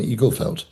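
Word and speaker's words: Igelfeld: Eaglefelt.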